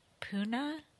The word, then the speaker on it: Pune: I think all of her biggest fans probably It's pronounced Puna. I don't think they're even pronouncing it correctly.